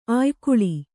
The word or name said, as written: ಅಯಿಗುಳ